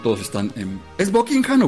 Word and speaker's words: Buckingham: Es Buckingham. – Eso sí, y de hecho, inglés perfecto, el H medio que se es medio silente. Buckingham.